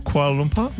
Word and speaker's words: Kuala Lumpur: Is it, Sarah, is it Kuala Lumpur? – Yeah, you said it right. – Kuala Lumpur? – Yep.